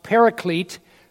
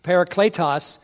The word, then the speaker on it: paraclete: When the helper comes, that word, in Greek it's pronounced paraclete, parakletos, the paraclete, he's going to help you.